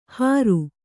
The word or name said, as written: ಹರು